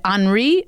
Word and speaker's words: Henri: So what I have for you is the Henri Fessy, or Henry Fessy in the American pronunciation.